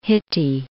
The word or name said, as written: Hitti